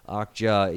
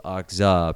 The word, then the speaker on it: Okja: But still, I'm just going to say Okja because the commenter from Korea said Okja equals Okja pronunciation, like they spelled it out for us.